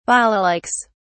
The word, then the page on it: balalaikas: British English